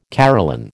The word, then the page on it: Caroline: American English